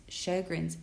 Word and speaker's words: Sjögren's: Now, Sjogren's is spelled S-J-O-G-R-E-N-S. And it's Sjögren's syndrome.